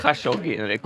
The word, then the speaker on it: Khashoggi: Förlåt, jag tänkte på en Jamal Khashoggi. Det låter som ett såhär... – jag tror inte det är så man uttalar det. – Är det inte det? – Khashoggi.